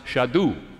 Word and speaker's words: Shadu: It's pronounced Shadu. Same consonants, same principles, same idea, same seminal language.